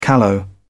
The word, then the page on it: callow: British English